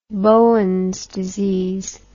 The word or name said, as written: Bowen's disease